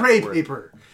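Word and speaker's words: crepe: It's creepy paper. – Creepy. I always mispronounce that. – Oh, cray paper.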